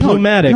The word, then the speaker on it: pneumatic: Well, yeah, it's like naming your kid Pneumatic. – No, you don't pronounce the P in that.